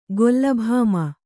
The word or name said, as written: ಗೊಲ್ಲಭಾಮ